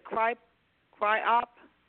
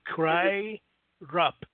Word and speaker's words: CRYROP: How you pronounce that? Cry op? – No, cry rap. Yeah, cry rap. Cryrop dot org.